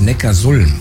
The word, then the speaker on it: Neckarsulm: Habe ich das richtig ausgesprochen? – Nee, ich glaube, das heißt Neckarsulm. – Ja, und warum? – Ja, weil es in der Datenbank steht. – Nee. Und der heißt deshalb Neckarsulm, weil Ulm liegt an der Donau.